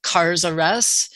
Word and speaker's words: Cars R Us: And we partner now with a company called Cars R Us, and that's spelled with the K, and it's like Toys R Us, so it's Cars and then the letter R and S.